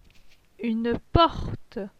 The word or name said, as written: Une porte